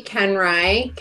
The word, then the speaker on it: Koenreich: So my name is Kelsea Koenreich. Ken like the doll, Rye like the bread.